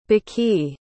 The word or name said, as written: baqi